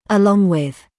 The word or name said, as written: along with